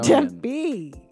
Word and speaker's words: Tempe: And you got to say it like a real Arizonan. – Tempe.